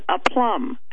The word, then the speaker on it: aplomb: APLOMB. You know, you do something with dignity or grace, and he called it a plum, and I said it's a plum. So Lou looked it up, and the first pronunciation was a plum, and the second one, I guess I have to give it to Chucky, it was okay.